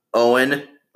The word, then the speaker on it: Eoin: And then Owen. Not Owen as like O-W-E-N. This is a not, it's kind of a Celtic Gaelic, another Irish name. It's like, it's spelled E-O-I-N. E-O-I-N. Yeah. Owen, but it sounds like Owen. Owen. But spelled E-O-I-N.